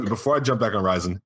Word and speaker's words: Ryzen: Yeah, Ryzen.